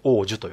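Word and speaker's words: 桜樹: 桜の木と書いて王樹と読むんですけど、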